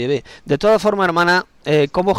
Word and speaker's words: Yahvé: la verdadera pronunciación en hebreo arcaico del Dios de Israel es Yahvé. Yahvé. No Jehová ni tampoco Yahvé, es Yahvé.